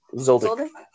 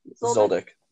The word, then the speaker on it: Zoldyck: Zoldyck, I think. – Zoldyck. – Zoldyck.